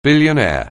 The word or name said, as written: billionaire